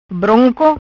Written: Bronco

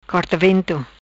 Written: Corta Vento

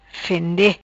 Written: fende

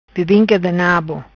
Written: Bebinca Di Nabo

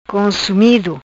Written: Consumido

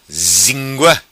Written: Zingua

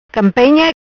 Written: Campenha

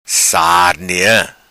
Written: Sarnea

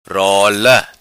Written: Rola

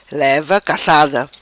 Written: Leva cacada

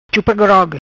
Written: Chupa Grog

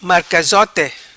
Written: Marcazote

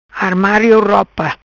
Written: Armario Ropa

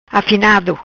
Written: Afinado

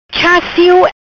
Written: Cha Sio

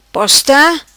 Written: Posta

